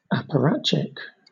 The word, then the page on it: apparatchik: British English